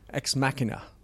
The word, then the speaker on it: Ex Machina: Ex Machina? – Ex Machina. Ex Machina. Oh, yeah. – How do you say that? – Ex Machina. – Machina. Ex Machina. – It's more Latin.